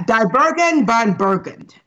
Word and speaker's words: Die Burgen von Burgund: Yeah, Die Burgen von Burgund.